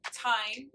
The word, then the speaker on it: thyme: Today's fresh herb that I picked is called thyme. The H is silent the nutritional source of thyme is it's a great source of vitamin C, vitamin A, potassium.